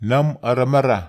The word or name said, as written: nam aramara